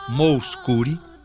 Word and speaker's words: Mouskouri: Por isso, o exemplo de Nana Moscuri, um nome estranho, Moscuri se escreve M-O-U-S-K-O-U-R-I, Moscuri.